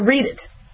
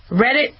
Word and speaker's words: Reddit: Is it pronounced Reddit or read it? Reddit? All right.